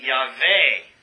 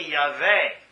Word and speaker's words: Yahweh: From that we get the name Yahweh, but that's a kind of a corruption of the translation. It should be Yahweh, because it's Yud-Heh-Vav-Heh. So it should be Yahweh.